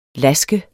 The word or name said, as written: lask